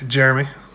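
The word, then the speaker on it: Jerami: They lost Jeremiah Grant. – Jeremy. – Whatever. I say Jeremiah because that's how it's spelled.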